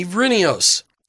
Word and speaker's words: Vrinios: We've got Bob Williamson in studio with us today and Jamie Vrinios.